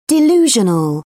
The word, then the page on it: delusional: British English